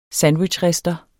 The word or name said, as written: sandwichrister